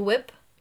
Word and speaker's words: whip: Like W-H-I-P. And so when I see that word, I say whip. Whip. Yeah. And I said, does anybody else pronounce the H in that word? And they all said, no, we say whip, W-I-P.